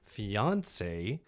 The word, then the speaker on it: fiancé: The first one is fiancé, mm-hmm. with the emphasis on the syllable of the middle one.